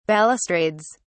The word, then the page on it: balustrades: British English